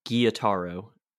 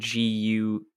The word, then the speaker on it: Gyutaro: And to answer the question, apparently he did say his name is Gyutaro, is how I would pronounce it. Gyutaro.